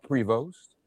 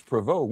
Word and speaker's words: Prevost: It's not Prevost. It's Prevost.